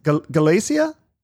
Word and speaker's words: Galicia: Is Galicia? – Yeah.